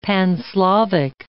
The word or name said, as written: Pan-Slavic